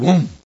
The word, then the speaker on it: womb: It's called womb.